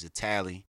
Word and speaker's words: Athalie: But how you say my name and pronounce my name is Italy. The H is silent, don't forget that.